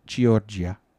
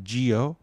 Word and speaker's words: Giorgia: Giorgia. It's like Georgia, but Giorgia.